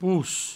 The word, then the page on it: pos: pous; provençal